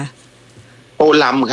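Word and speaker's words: Tô Lâm: อ่านว่าโตเลิมหรือโตลำคะโตลำครับ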